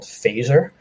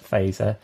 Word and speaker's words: Fazer: I am the Chief Strategy Officer at a branding agency in New York called Phaser. F-A-Z-E-R is how you spell it.